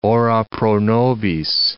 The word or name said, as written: ora pro nobis